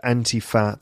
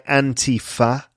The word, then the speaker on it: Antifa: So number two, how do you pronounce Antifa? According to Bray, the guy who wrote the anti-fascist handbook, there are multiple different pronunciations. In the United States, most people say Antifa. In Europe, it's sometimes referred to as Antifa. So Antifa, Antifa, which Bray believes has been influenced by the Italian word antifascismo. He says Antifa, but doesn't correct people who pronounce it differently.